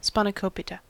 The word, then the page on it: spanakopita: American English